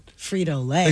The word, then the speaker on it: Frito-Lay: I think the emphasis is on the lay. – I hate this because now I'm going to be in my head. – Frito-Lay.